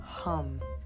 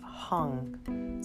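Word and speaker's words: Ham: The mantra that is affiliated with the throat chakra is HUM. It's H-A-M. And it's pronounced hum. So as if we're humming. And see if there's just a couple other things. So I see it's pronounced hum, but then I also see it can be pronounced as hung. So H-A-N-G.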